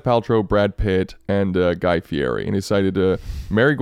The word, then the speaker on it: Fieri: And by the way, it's pronounced Fieri.